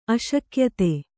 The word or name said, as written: ಅಶಕ್ಯತೆ